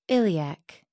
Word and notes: iliac: American English

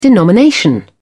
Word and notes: denomination: British English

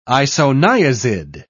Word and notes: isoniazid: British English

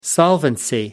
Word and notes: solvency: American English

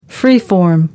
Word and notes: free form: American English

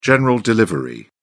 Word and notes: general delivery: British English

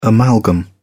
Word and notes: amalgam: British English

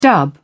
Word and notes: dub: British English

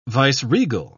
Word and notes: viceregal: British English

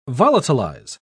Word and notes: volatilize: British English

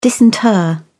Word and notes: disinter: British English

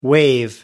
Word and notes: waive: American English

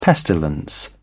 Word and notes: pestilence: British English

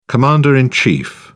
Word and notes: commander in chief: British English

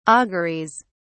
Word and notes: auguries: British English

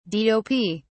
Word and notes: dop: British English

